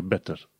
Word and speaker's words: better: Să zicem, hai să vedem dacă a dat ea vreun exemplu pe undeva, dacă a scris, da, uite, e better. De exemplu, în uh, engleză americană, când citești better, cumva se pronunță better, better, cu D. Dar în engleză britanică trebuie să pronunți cu T, better, better, butter, butter.